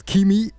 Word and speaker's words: Keeme: Keeme is spelled K-E-E-M-E. I hope we've been pronouncing this name right this whole time, guys, because Keeme. – What's the pronunciation for this? – It's Keeme, guys. Come on, bro.